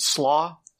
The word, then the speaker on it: SLA: But apparently this was the super light ablator, or was SLA, but they pronounce it SLAW, SLAW 561.